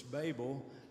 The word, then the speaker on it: Babel: If you ever notice, that's a long A on that word. It's Babel.